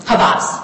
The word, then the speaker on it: Havas: Okay, let's say that you hadn't settled with, and is it pronounced Havas? – Havas.